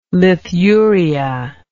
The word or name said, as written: lithuria